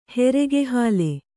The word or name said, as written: ಹೆರೆಗೆಹಾಲೆ